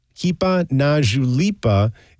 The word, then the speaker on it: Hipa na julipa: Hipa na julipa is how you say it, and it roughly translates to hit it hard or go for it.